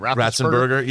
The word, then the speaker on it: Ratzenberger: Ratzenberger.